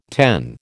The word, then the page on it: ten: American English